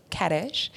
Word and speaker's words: Ketesh: So we switched it up and we called her Ketesh, which everyone thinks it rhymes with fetish and has nothing to do with that, but it's pretty convenient.